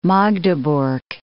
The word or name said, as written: Magdeburg